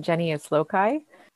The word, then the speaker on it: Genius Loci: How do you pronounce it? – We would probably pronounce it Genius Loci.